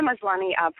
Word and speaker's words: Maslany: Is that how we correctly pronounce her name? – It's perfect. Tatiana Maslany, probably most of you would know from Orphan Black, where she plays the many, many clones of Orphan Black.